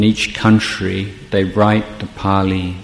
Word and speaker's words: Pali: Now, you notice I pronounce that as Pali rather than Bali.